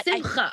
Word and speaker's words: Simcha: It's the Simcha Award. You have to make that ch sound. It's in Chanukah, that same sound.